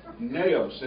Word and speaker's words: naos: And that's what I need here, but it's the word naos, pronunciation N-A-H-O-S, but naos.